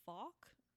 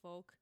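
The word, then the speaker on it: Fouke: Some people pronounce it Falk. Some people pronounce it folk. So I looked it up and apparently it is supposed to be folk.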